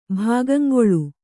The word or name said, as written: ಭಾಗಂಗೊಳು